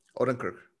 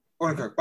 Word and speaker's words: Odenkirk: So the whole thought for this script initially came from Bob Oaken? – Odenkirk. – Odenkirk. Bob Odenkirk.